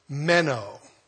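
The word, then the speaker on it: menō: The Greek word translated abide in my New King James here is meno, M-E-N-O with a long O, meno.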